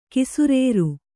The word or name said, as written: ಕಿಸುರೇರು